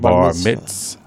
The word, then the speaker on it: bar mitzvah: Hey, excuse me, I seen a Barbara Bispa down here. How do you pronounce that word, by the way? Just help me out. – Bar mitzvah. – Oh, bar mitzvah.